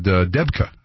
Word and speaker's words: debka: There were little musical groups on the street, and there were debki. Is that how you say it? Debki line, sort of a dance line. In fact, they said debka. I think that's it.